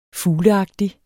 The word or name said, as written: fugleagtig